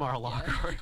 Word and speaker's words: Mar-a-Lago: Is that how you pronounce it? Mar-a-Lago. Yeah.